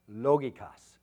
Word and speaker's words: logikos: The second adjective to describe this milk in the original Greek, again, is it's pronounced logikos, all right? Which can mean spiritual, but also it can mean logical, rational, reasonable. And that particular word, logikos, is found only two times in all the Bible.